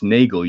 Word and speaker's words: Nagel: Just a note, my surname's pronounced Nagel. You pronounce it like Richard Case Nagel, perhaps.